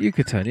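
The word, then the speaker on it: Yucatán: Would you pronounce it like that? Or why Yucatan? Yucatan. – Yes. Mekican. That's Yucatan, isn't it? – Yucatan, yeah. Mexican.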